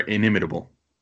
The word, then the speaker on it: inimitable: inimitable, if I'm saying that correctly. Is that how you say that? – Yes, it is. – Yeah. You are inimitable.